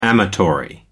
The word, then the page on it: amatory: American English